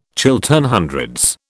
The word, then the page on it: Chiltern Hundreds: British English